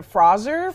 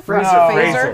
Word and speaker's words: Fraser: And Adam Sandler couldn't say his name, but then I guess Brendan Fraser's name is pronounced Brendan Fraser. – Fraser. For the hard Z. Fraser. – It's a hard Z, yeah.